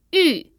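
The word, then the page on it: yu4: tone 4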